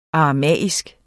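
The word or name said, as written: aramæisk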